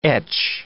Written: etch

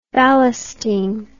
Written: ballasting

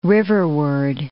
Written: riverward

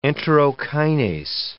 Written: enterokinase

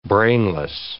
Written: brainless

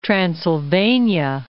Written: Transilvania

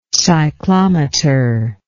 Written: cyclometer

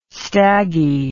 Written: staggy

